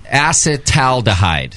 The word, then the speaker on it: acetaldehyde: Because it's not acetaldehyde. I'm trying to pronounce it right. It's acetaldehyde.